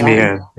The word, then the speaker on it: Meighan: I am assuming it's Meehan. It's spelled M-E-I-G-H-A-N. – It is Meehan, Thomas Meehan.